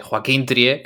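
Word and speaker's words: Joachim Trier: Bueno, lo primero de todo es que te has complicado mucho con eh, eh, Joaquín Trier, que es un nombre bastante sencillo de pronunciar, has querido poner acento ahí noruego.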